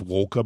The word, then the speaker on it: Walcha: Walker, spelled W-A-L-C-H-A. – Yes. – Pronounced Walker, but it's Walcher.